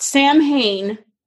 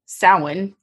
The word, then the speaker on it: Samhain: So Samhain, not Samhain, Samhain.